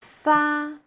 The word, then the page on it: fa: fa1